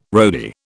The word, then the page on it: roadie: British English